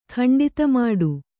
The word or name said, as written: ಖಂಡಿತಮಾಡು